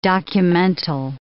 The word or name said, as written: documental